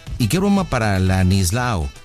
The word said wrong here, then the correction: Ladislao: Es Ladislao. – ¿Con E o con L? ¿Cómo está? – L. – A ver, Lanislao. Por eso, lo dije bien, ¿no? – Ladislao. – Ladislao. Ladis. Ladis no. Con D. Ladis. Ladislao.